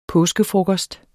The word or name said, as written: påskefrokost